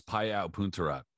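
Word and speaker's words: Payao Poontarat: or I think Hiro Watanabe against Pio Punarat. I'm probably butchering his last name really badly, but you're really close. Pio Punarat.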